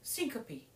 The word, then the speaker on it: syncope: Syncope? – Syncope.